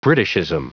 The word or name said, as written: britishism